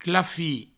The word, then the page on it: clafir: clafi